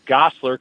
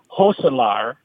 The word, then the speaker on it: Gosselaar: He said, in fact, the way you pronounce my last name is Hoselaar. It's Dutch.